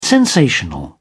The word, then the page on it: sensational: British English